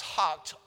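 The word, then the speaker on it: houghed: And David, though it looks like hewed, I believe this word is pronounced hocked, all the chariot horses, but reserved of them for 100 chariots.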